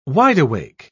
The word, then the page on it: wide-awake: British English